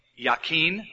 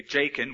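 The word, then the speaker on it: Jachin: He set up the pillar on the right and called its name Yakin, or we would say Jakin, we'd mispronounce it, and set up the pillar on the left and call its name Boaz, or Boaz.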